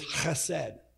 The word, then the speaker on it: chesed: It's C-H-E-S-E-D is how you would pronounce it, write it if you're translating from Hebrew. It's chesed.